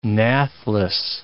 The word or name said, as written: nathless